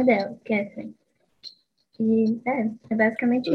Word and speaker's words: Catherine: Mas pelo que você citou, o Heathcliff tem um ciúme do Edgar pela Catarine, pela Catherine, né? Como se fala em inglês.